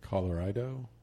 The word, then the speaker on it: Colorido: It is Studio Colorido. It's spelled like color, then Ido. – Yeah. – Colorido.